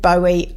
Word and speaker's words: Bowie: Okay, we are going on to David Bowie now. This is Modern Love. I've pronounced it wrong. It's David Bowie.